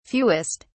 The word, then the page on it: fewest: British English